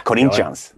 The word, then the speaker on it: Corinthians: Spelade i Fiorentina, framförallt känd för sin tid i Corinthians. Där han var det var ett riktigt dåligt uttal. Corinthians kanske? – Corinthians. Jag vet, jag vet, portugisiska, ja.